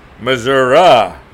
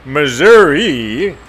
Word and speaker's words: Missouri: No, Missouri, not Missouri. – Oh, I was saying it wrong? – Yes, ask a native Missourian.